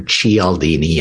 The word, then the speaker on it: Cialdini: And I'm not sure if you know the works of a social scientist by the name of Dr. Robert Cialdini, or Cialdini, as he pronounces it.